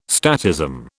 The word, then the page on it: statism: British English